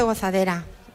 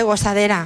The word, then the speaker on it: gozadera: No se dice gozadera, se dice gozadera.